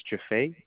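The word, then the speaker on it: Jih-Fei: First name is Jaffe. Would you like me to spell it? – Yes, please. – Okay. J-I-H hyphen F like Frank, E-I.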